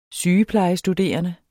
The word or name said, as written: sygeplejestuderende